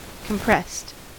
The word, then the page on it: compressed: American English